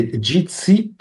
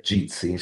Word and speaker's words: Jitsi: Meet Jitsi, Jitsi Meet, e si scrive J come Juliet, Imola Torino, JIT.SI, Savona Imola, però uno se lo cerca con Jitsi, con la J, lo trova.